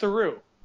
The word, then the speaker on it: Theroux: His father is Paul Theroux, and you pronounce it Theroux, like true. – Yeah, he like... Not Theroux.